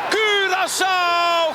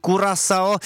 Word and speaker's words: Curaçao: Curaçao gaat naar het WK! – Ano, trošku odlišná výslovnost od televizního komentátora, nicméně v Česku máme úzus výslovnosti Curaçao a takhle komentoval ten zmíněný hlas postup téhle malé ostrovní zemičky na fotbalový mundial.